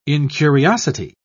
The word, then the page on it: incuriosity: British English